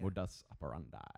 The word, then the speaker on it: modus operandi: self-selecting sample size because that was the modus operandi back in, did right. – I pronounce that right? – Yeah. – All right. – You got that one right. Yeah. – Modus operandi.